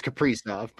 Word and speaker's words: Kaprizov: I'm pretty sure it's Kaprizov, but either Kaprizov, way, it's fine. Yeah, Kaprizov.